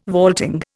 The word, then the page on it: vaulting: Indian